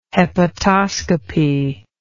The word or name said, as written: hepatoscopy